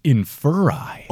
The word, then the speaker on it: Inferi: In my head, it's Inferi. – Yeah. I would say Inferi. – Okay. – Stephen Fry says Inferi. – Oh, wait, I kind of like that. – Like Inferi.